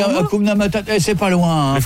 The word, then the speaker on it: Hakuna Matata: Hakuna Matata. – Non, c'est Hakuna Matata.